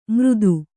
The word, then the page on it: ಮೃದು: mřdu